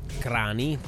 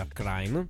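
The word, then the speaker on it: Kranj: Ultima segnalazione per venerdì sera in Slovenia, abbiamo Akrain, Crani come si dice a Trieste, in realtà si pronuncia Akrain.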